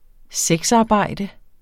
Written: sexarbejde

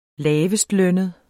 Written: lavestlønnet

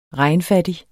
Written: regnfattig

